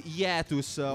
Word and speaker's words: hiatus: In quel periodo, esatto, un periodo di hiatus, come si dice? Hiatus si dice? Bene, hiatus, mi dicono. – Hiatus. – Su Wikipedia com'è che si pronuncia? Hiatus? – Puoi dire anche hiatus.